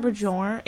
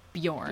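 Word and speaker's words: Björn: Peter Bajorn? Is the B silent? – The BJ is like a Y, so it's like Bjorn.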